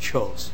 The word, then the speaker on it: Kjos: My name is Chuck Chos. My last name is spelled K-J-O-S.